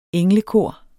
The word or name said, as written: englekor